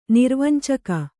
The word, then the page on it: ನಿರ್ವಂಚಕ: nirvancaka; Kannada